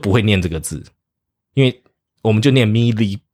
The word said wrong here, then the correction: melee: 然后直到有一天有人讲说 melee，然后我就 oh is that how you pronounce it？